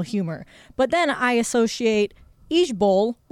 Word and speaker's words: IJBOL: In lieu of laugh out loud, it's IJBOL. – IJBOL. – Pronounced Ejbol. – Nope. Too many letters to remember. – Ejbol.